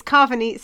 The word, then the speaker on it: Carvanista: So last episode we said it wrong the whole time. It's Carnavista. No, it's Carvanista. Sorry, it's Carvanista. – I don't know which way around. – It's not like carnivore.